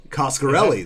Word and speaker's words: Coscarelli: Coscarelli.